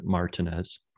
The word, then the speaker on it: Martinez: It's spelled Martinez, but they pronounce it Martinez, accent on the A.